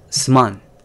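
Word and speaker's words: suman (すまん): And then for me it's also sorry or excuse me, it's suman.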